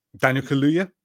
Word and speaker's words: Kaluuya: Daniel Kaluuya? – Yeah, yes.